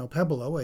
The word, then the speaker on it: Okpebholo: The set is titled Two Black Churches, and the composer is Sean Opebolo, a Chicago composer. And that's spelled O-K-P-E-B-H-O-L-O. The K is obviously silent.